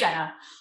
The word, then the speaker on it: Innsena: Yeah, it's Insana.